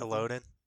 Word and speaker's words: Elodin: Elodin.